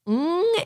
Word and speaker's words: Ng: His Leaving LA has an amazing video done by T. Ken Ng, N-G, I think that's how you say it.